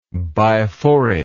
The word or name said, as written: biforate